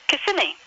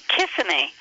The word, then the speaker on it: Kissimmee: You put the emphasis on the incorrect syllable, and it just changes the whole thing. Like Kissimmee. And having Kissimmee. Walden, it's spelled, it begins with the letters K-I-S-S. – Kiss, uh-huh. – So the tendency is for people to call it Kissimmee. – Hmm. – And it's an Indian word.